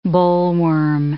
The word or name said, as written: bollworm